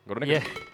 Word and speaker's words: Groningen: It's like Groningen. – Right. – So you got to get Groningen. The G, like Groningen.